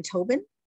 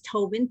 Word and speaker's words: Tobin: I'm on Facebook, Deanne Tobin. – You want to spell that for people? Do you want to spell? – So the last name is Tobin, T-O-B-I-N.